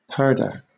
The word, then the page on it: purdah: British English